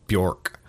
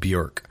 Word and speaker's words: Björk: A lot of people pronounce her name Björk, but it's actually pronounced Björk.